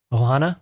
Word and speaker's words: Ohana: Ohana.